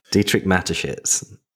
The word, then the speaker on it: Dietrich Mateschitz: Dietrich Mateschitz.